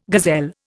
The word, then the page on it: gazelle: Indian